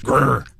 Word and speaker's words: GR: It's the Toyota GR, which is pronounced Grr.